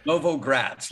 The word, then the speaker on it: Novogratz: And is it Novogratz? Is that how you pronounce that? – Novogratz, like new city in Russia.